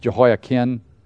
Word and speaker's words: Jehoiachin: Now, again, it's actually pronounced Jehoiachin, But I always pronounce it Chin because when I'm doing this, Jehoiachim and Jehoiakin, I mean, it's two.